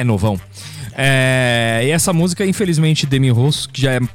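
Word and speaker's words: Demi Roussos: Demi Rousseau. Como é que fala mesmo? – Demi Rousseau. – Isso aí.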